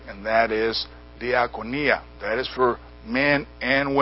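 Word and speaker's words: Diakonia (διακονία): Diakonion, and it is spelled D-I-A-K-O-N-I-A-N. Diakonion. From diakonia. D-I-A-K-O-N-I-A. Diakonia.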